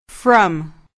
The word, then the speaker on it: from: From. – دقت کنید که برای تلفظ این کلمه نگویید فرام، باید بگویید فرام، یعنی حرف F و R باید به صورت سریع و بدون فاصله بیان شوند.